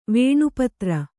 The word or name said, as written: ವೇಣುಪತ್ರಿ